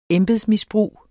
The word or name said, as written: embedsmisbrug